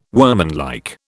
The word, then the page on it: workmanlike: British English